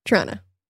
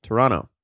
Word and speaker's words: Toronto: Toronto. – You don't say the second T, bro. – Toronto.